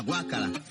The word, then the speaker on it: Guácara: Y Luis Mariano Rivera, el cantor de Canchunchú, dijo a Gualberto: toma pichón, me la cantas tú, la guácara, como guacara, pero con acento en la G.